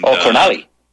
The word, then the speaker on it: Connelly: And, Connelly. – Connelly is how they pronounce it over here in eastern Kentucky.